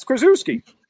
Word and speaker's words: Krzyzewski: And gee, don't let anybody ever tell you it's Krzyzewski, Krzyzewski, Coach Krzyzewski. – I know. I've heard it that way before, actually. – It's how it's spelled. It's Krzyzewski.